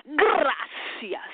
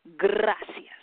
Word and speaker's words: gracias: Gracias. Somebody say thank you. Gracias. You roll it. Gracias. Or you can say gracias. Gracias. Or if you really want to pronounce it, gracias.